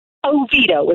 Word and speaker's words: Oviedo: Oviedo is what Oviedo.